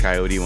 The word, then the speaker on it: Kaiyote: Let's do a track by Hiatus Coyote, one of my favorite bands. It's spelled H-I-A-T-U-S. Well, that's how you spell Hiatus. But Coyote, K-A-I-Y-O-T-E.